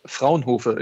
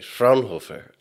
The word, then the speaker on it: Fraunhofer: Fraunhofer, it is. – Okay, Fraunhofer. – Yeah, perfect.